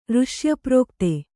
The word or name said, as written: ಋಷ್ಯಪ್ರೋಕ್ತೆ